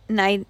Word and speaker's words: naivety: kind of plays into maybe Evangeline's own, I'm going to say this word wrong, naivety. – Naivety, thank you. I can always say naive. It's the itty that loses me.